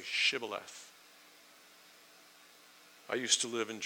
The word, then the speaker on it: Shibboleth: Shibboleth. And he would say, "Sibboleth." For he could not pronounce it right, because the Ephraimite language does not have the sh sound.